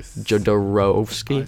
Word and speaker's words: Jodorowsky: I, I Jodorowsky.